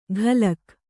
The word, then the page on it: ಘಲಕ್: ghalak